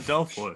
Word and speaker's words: adelphoi: And in the book of 2 Timothy, when Paul writes to 2 Timothy, he uses this word aldaphoi to describe adelphoi. I'm sorry, I don't speak Greek. – I think it's adelphoi. – Adelphoi? Yeah. My bad.